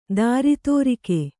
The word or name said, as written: ದಾರಿತೋರಿಕೆ